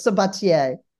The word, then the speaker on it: Sabatier: You're close, Sabatier. – Sabatia. It's French.